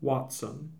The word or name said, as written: Watson